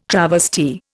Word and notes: travesty: Indian

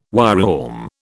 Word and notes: wireworm: British English

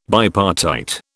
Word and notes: bipartite: British English

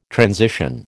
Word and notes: transition: American English

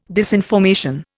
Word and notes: disinformation: Indian

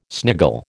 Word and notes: sniggle: American English

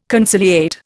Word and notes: conciliate: Indian

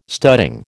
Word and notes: studding: American English

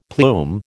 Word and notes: plume: American English

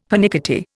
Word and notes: pernickety: Indian

